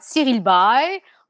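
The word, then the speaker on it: Cyril Baille: So Cyril, what how do you pronounce his uh, surname? – That's right, Cyril Bay.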